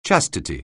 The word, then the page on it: chastity: anglais